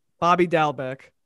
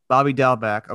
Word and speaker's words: Dalbec: Bobby Dalbec. – Bobby Dalbec.